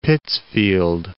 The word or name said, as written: Pittsfield